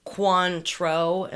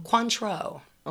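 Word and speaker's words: Cointreau: She said it's phonetically pronounced Quantro. Am I pronouncing that correctly? It's in the parentheses. – Quantro.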